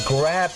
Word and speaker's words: grab: grab me a pizza so ที่จริงแล้วในภาษาอังกฤษคำว่า G R A B ออกเสียง grab คล้ายๆกับกินกราบเนี่ยนะครับ